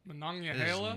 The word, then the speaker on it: Monongahela: I don't know, I always go like Monongahela. – That sounds like it's in Hawaii. – Monongahela? – It is not. Yeah, that's right.